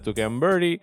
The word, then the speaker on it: Tuca and Birdie: Yeah, eh, de las primeras dos temporadas de Tuca and Birdie.